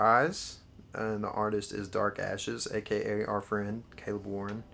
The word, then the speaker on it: DVRKVSHES: But anyway, it's Dark Ashes. It's spelled all caps DVRKVSHES. I know V usually sounds like V, but in this case it's ARK.